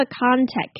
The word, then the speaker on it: contact: Using a contact case，这边的 contact 的 t 呢，讲者是没有发音的，因为后面是 case，直接把 contact case 连在一起。